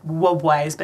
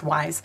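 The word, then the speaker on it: Wwise: Looks like WUBWISE, but it's just pronounced WISE.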